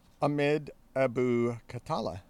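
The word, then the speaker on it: Ahmed Abu Khattala: Ahmed Abu Katala.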